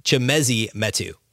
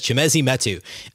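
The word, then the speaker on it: Chimezie Metu: Real quick, correcting my mispronunciation. It's Chemezi Metu. I should have had that ready, and I apologize. Chemezi Metu is how you say the name of the Kings big man who has started to play a bit more for them of late.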